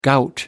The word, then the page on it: gout: American English